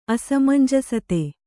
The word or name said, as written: ಅಸಮಂಜಸತೆ